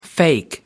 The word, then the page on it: fake: American English